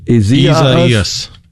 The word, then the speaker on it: Isaias: Isaias. – Isaias. Gotcha. So an extra syllable in there, it would appear, from the way it's typically pronounced, spelled I-S-A-I-A-S.